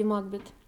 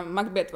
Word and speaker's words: Макбет: И о какой же именно Леди Макбет? Ну, о нашей лисковской, конечно, потому что шекспировская с другим ударением, она Макбет вообще-то.